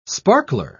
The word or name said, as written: sparkler